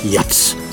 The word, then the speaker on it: Jazz: Denn die einen sagen Jazz, die anderen Jazz. Bei dem von Honda produzierten Auto bin ich mir sicher, dass er trotz der Schreibweise JAZZ mit Ä ausgesprochen wird.